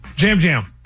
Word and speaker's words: Yam Yam: Yam Yam? – Jam Jam. – Oh, it's spelled Yam Yam, though. – Yeah, but he's from Puerto Rico, so I guess they say it Jam Jam.